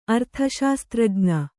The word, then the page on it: ಅರ್ಥಶಾಸ್ತ್ರಜ್ಞ: kannada